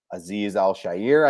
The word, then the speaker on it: Aziz Al-Shaair: They brought in, I might butcher his name, Aziz Al-Shaair, I think is the correct pronunciation.